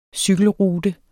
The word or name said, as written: cykelrute